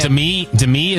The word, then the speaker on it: Demi: Demi is the right way to say it, according to her.